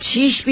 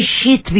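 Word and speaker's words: شیث: آپ شیش بھی کہہ سکتے ہیں، شیت بھی کہہ سکتے ہیں، S H E I T H.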